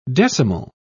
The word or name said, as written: decimal